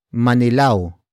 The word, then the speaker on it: manilaw: Word for word it's, baka, manilaw, ang, kubiertos.